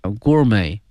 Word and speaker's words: gourmet: 复杂的哈一道美食了自古以来它就以肉类为主但是啊现在呢蔬菜呀、啊、主食甚至水果哈都可以用来烧烤风味啊与众不同 For thousand years grilling has evolved from a primitive cooking form它发生了一个演变哈用这个词 evolve, E-V-O-L-V-E, 哈这个词表示一个演化一个变化 from a primitive cooking form to sophisticated gourmet delicacy, gourmet, G-O-U-R-M-E-T, 后面这个 T 是不发音的 gourmet 表示美食的一个 delicacy, 哈 it has been meat-based since ancient times,很早以前哈它是以肉类哈为主的哈叫一个 meat based, B-A-S-E-D, 我们学过哈 be based, 以什么什么为基础。But now vegetables, staples and even fruit are cooked this way for unique flavor.